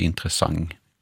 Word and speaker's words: intressant: Det här uttalet då som Nanna nämner, intressant, det finns också belagt i man kan slå upp i SOB och se att det här har kunnat uttalas intressant med ett ng på slutet. Då. Så det blir ju intressant.